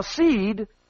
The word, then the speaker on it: Cede: Now, Seed is spelled C-E-D-E, Seed and Company.